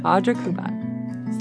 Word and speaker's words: Audra Kubat: Audra Kubat.